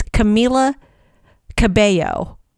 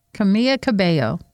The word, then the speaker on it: Camila Cabello: So, a lot of people pronounce Camila Cabello's name wrong. That's how to say it. I didn't know for the longest time either. It's Camila Cabello. – Camila Cabello.